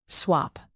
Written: swap

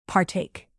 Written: partake

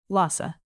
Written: lhasa

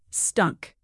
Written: stunk

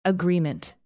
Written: agreement

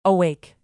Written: awake

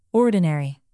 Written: ordinary

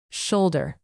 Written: shoulder

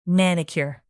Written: manicure

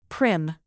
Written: prim

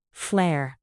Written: flair

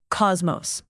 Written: cosmos

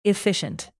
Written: efficient

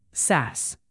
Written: sass

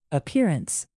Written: appearance

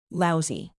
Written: lousy

